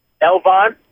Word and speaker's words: Elbon: Chuck has spelled it E-L-B-O-N. How do you pronounce it? Elbon? – Elbon.